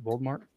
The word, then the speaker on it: Voldemort: Did I say that right? Voldemort? – Yeah.